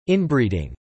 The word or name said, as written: inbreeding